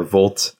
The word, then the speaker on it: vlt: But VLT, or Volt, as I like to pronounce it, is a net new package manager, a JavaScript package manager, as well as a new registry.